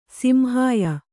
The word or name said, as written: ಸಿಂಹಾಯ